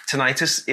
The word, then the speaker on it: tinnitus: he's got tinnitus, that everyone calls it tinnitus, that my wife was like, no, tinnitus is, itis means a swelling. So it's tinnitus is technically how to pronounce it.